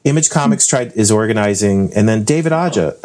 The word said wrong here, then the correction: Aja: Aja, wait, wait, Aha.